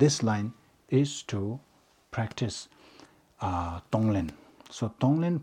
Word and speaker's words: Tonglen: Tonglen, there are two syllables. Tong, to give. Len, to take. Tonglen.